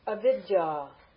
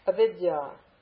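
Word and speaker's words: Avidya (अविद्या): Avidya. Avidya. Short a, short e, then dya, long ah, avidya.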